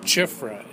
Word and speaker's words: Cziffra: Anything online or One person I just discovered was named Chifra, and it's kind of like, it's spelled sort of like Cherney, C-Z-I-F-F-R-A, and